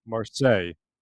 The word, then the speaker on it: Marseille: Marseille. Oh, you're fancy like that. – Well, I mean, that's just how it's pronounced, man, you know? – Not your pronunciation.